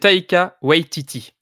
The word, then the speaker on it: Taika Waititi: Donc, Taikiki, ça se passe là-bas. – C'est Taika Waititi. – Taika, pardon. Ouais, tu vois, je l'ai complètement écorché son nom. J'ai mélangé prénom et nom.